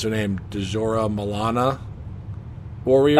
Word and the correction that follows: Dora Milaje: Oh, the Dora Milaje? – Dora Milaje.